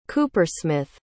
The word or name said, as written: coopersmith